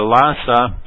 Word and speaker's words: Lhasa: I'm going to talk a little bit about the new China railway, Beijing to Lhasa.